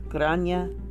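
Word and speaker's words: Gráinne: Grania is pronounced, it's spelt G-R-A-I-N-N-E.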